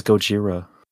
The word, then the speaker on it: Gojira: The fuck is Gojira? – That's how they pronounce Godzilla in Japan. – Oh. – Yeah. Gojira!